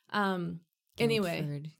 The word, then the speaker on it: Guildford: Guildford. – Get out of here. – Huh? Guildford. It's a silent D.